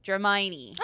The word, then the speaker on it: Dramione: Dramione.